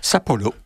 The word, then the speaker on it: Sapporo: Sapporo, pour le prononcer à la japonaise,